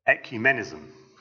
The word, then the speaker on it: ecumenism: It's the idea of ecumenism.